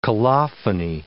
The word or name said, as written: colophony